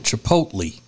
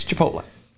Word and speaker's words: Chipotle: And the reporter, though, something that stood out to me in the full story, the reporter pronounced it Chipotle. – Yeah, I hated that part. – Chipotle? – Chipotle. Oh, Chipotle. – Yeah. – No, sir, it's Chipotle.